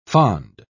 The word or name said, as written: fond